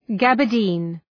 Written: gabardine

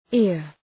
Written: ear

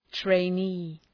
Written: trainee

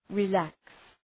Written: relax

